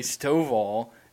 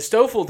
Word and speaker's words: Stoffel: You heard it because you heard the wrong pronunciation of what would be Stovall, because Christian used to call him Stovall. Okay. Anyways, the Stoffel dude was pumping like 94, 95 through like 4 innings.